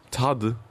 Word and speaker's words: Tadı: Tade. – Tade. – Dieses I ohne I-Punkt ist ein Ö.